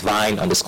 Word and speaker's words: Vyne: My name is Vine _ Henshaw. Vine is spelled V-Y-N-E _ Henshaw.